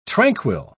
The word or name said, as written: tranquil